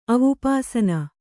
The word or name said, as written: ಅವುಪಾಸನ